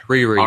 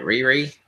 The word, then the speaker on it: Riri: So she's a, I can't pronounce the name, Riri? – Riri. – R-I-R-I, Riri? – Riri, yeah.